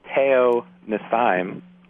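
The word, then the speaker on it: Te'o-Nesheim: And along with that, we'll probably see the new acquisition, Darryl Tapp, on the inside, along with third-round pick Daniel Teo Nassim, I believe is how he pronounces it. – Yep, you got it.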